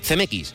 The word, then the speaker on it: Zemeckis: Y su director, a ver si lo digo, fue Robert Zemeckis, ¿puede ser? – Zemeckis.